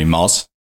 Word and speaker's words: Moz: So part of it is from when I was a kid, everybody used to nickname me Moz. M-O-Z.